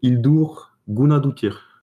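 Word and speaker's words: Hildur Guðnadóttir: Bon, enfin, moi, j'en ai un, mais c'est ma chronique, donc okay, t'as regardé comment ça se prononçait ? – J'ai écouté, surtout, parce que j'ai écouté le début d'une interview, et elle prononce son prénom et son nom, donc... C'est juste Hildur Gunnadóttir.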